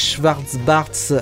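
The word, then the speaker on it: Schwarz-Bart: Et puis nous, on va s'écouter un morceau de Jacques Schwartz-Bartz.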